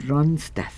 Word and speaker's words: Ronstadt: Ronstadt. Lo pronuncié bien. Sí.